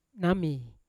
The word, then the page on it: nami: Yawa